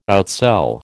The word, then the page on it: outsell: American English